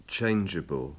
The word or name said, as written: changeable